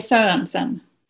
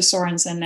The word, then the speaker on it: Sorenson: the Swedes would say Sorenson. – Sorenson. – But I'm quite used to Sorenson now, so that's fine too.